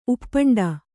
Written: ಉಪ್ಪಂಡ

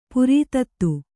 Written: ಪ್ರಥಿತ

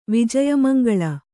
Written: ವಿಜಯಮಂಗಳ

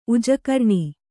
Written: ಉಜಕರ್ಣಿ

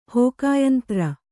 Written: ಹೋಕಾಯಂತ್ರ

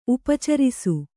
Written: ಉಪಚರಿಸು

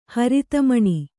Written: ಹರಿತಮಣಿ

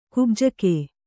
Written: ಕುಬ್ಜಕ

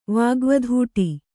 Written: ವಾಗ್ವಧೂಟಿ

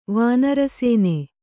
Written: ವಾನರಸೇನೆ

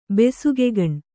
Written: ಬೆಸುಗೆಗಣ್

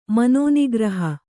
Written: ಮನೋನಿಗ್ರಹ

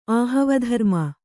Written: ಆಹವಧರ್ಮ

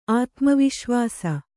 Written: ಆತ್ಮವಿಶ್ವಾಸ

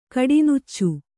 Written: ಕಡಿನುಚ್ಚು

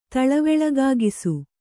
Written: ತಳವೆಳಗಾಗಿಸು

